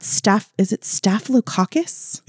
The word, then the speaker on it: staphylococcus: This is when Monjack and Sharon both caught staphylococcus? – It is, yeah.